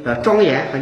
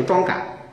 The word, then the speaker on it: 庄严: 我们念佛经的时候念了这么多错别字啊，我还见到一个居士念佛经庄严还念成庄感，因为那个言字里头是个感字，他把庄严念成庄感。